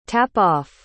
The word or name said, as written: tap-off